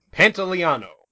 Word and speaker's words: Pantoliano: Pantoliano.